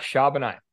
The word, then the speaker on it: Charbonnet: Oh, uh, Jackson Smith and Jigba and Zach Charbonnet.